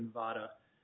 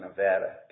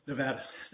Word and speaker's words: Nevada: We're here before you today, Your Honor, with regard to a district court action out of the District of Nevada. It was a granting of appellees. – That's pronounced Nevada, counsel. – Nevada, thank you.